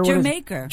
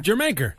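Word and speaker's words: D'yer Mak'er: Jamaica. – Jamaica. Like Jamaica. – Doesn't make any sense. – Yeah, they shouldn't have spelled it D-Y-E-R-M-A.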